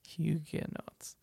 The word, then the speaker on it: Huguenots: I don't know if I'm pronouncing that right. It's Huguenots. Huguenots. Which country did they come from?